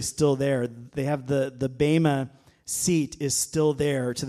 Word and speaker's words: bema: Did you know that that word judgment there is bema, or some pastors will say bema because it's spelled B-E-M-A, but I think it's more correct to say bema. B-A-Y-M-A is the pronunciation, bema, seat of Christ.